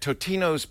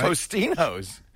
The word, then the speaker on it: Totino's: Who could forget Totino's Pizza Rolls? – Yeah, exactly. And I think it was because the first 6 months we were mispronouncing it. – We would call it, right? Tostino's.